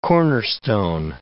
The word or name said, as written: cornerstone